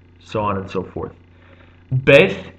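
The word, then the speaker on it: Beit (בית): Beth is the second letter, commonly pronounced Beit.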